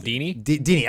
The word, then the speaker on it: Dini: but Paul Denis, I'm not sure how you pronounce the last name. – Paul Dini. – I want to call him Denis, but yeah, Paul Dini, the obviously the famous writer of Batman, of animated series, created Harley Quinn.